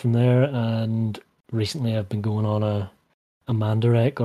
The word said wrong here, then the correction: Mandarake: or a, how did you pronounce it? Mandarake? – Mandarake. – Yeah, that's the snobbish way to say it, right? – Yeah, snobbish. I suppose it's correct, right? It's correct. – Yeah.